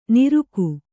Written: ನಿರುಕು

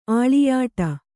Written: ಅಳಿಯೂಟ

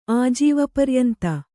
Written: ಆಜೀವಪರ್ಯಂತ

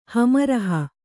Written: ಹಮರಹ

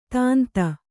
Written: ತೀಂತೆ

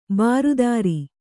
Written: ಬಿರಾದರ

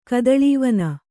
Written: ಕದಳೀವನ

